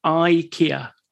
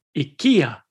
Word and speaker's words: IKEA: So we say in the UK and in the US, we say IKEA. But the Swedish way of pronouncing it is more like IKEA.